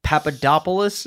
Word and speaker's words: Papadopoulos: The most recent one is George Papadopoulos. Is that? There's no way that's. How's that pronounced? – You actually pronounced that perfectly. – It's Greek.